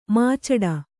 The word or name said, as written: ಮುಚ್ಚಟೆ